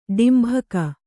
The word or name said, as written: ಡಾಂಬಿಕ